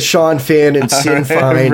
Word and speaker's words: Sinn Féin: The pronunciation of the IRA's political arm is Sinn Féin. – Sinn Féin.